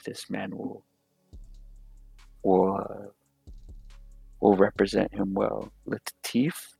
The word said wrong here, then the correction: Lakeith: if I'm pronouncing his, Lakeith Stanfield. Lakeith. I loved him, and Sorry to Bother You, it's probably one of my favorite movies.